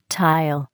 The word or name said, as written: tile